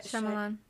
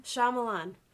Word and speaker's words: Shyamalan: Night Shyamalan. – How do you pronounce it again? – Shyamalan.